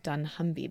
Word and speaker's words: Dunnhumby: So Blinkbox, this digital business, is being sold to TalkTalk, and it's looking at strategic options for the Dunnhumby business. I don't know if I pronounced that correctly. – I think you have.